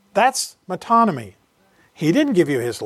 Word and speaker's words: metonymy: Well, you remember a few weeks ago that I mentioned to you a term, the term metonymy, spelled M-E-T-O-N-Y-M-Y.